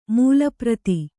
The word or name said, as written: ಮೂಲಪ್ರತಿ